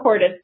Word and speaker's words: Hortus: Well, I was doing a rhyming book, so I had to, I named it rhymes with tortoise. So that's how Hortus came.